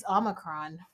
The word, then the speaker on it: Omicron: The Omarion. – The Omicron. How do you pronounce it? – It's Omicron. – Omicron.